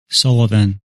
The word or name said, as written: Sullivan